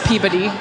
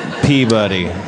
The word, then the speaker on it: Peabody: It's spelled P-E-E-B-U-D-D-Y is how it sounds. – Yes, Peabody. That's the one. – Peabody.